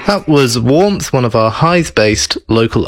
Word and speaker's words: Warmpth: Warmth is spelt W-A-R-M-P-T-H. So it's kind of, if you want to emphasize every letter, it's closer to Warmth.